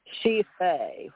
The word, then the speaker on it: Sidhe Fae: S-I-D-A-T. Okay, this may mean what you're more about. It says... She Fae, S-I-D-H-E-F-A-E, but you say it, D is silent. So S-I-D-H-E is she, like we say hers. She, I, you, he, she, it, we, you, they, she.